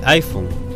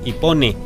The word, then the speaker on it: iPhone: También se ha lanzado una versión, digamos, en el 2009 fue para el iPhone. – Sí. – Ipone. – El Ipone. – Porque también es válido hablar, cuando uno no maneja un lenguaje, es válido hablar tal como se escribe, ¿no? – Claro.